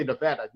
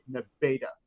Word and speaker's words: Nevada: Like, Nevada, like the state, they'll hate you if you say Nevada. You have to say Nevada.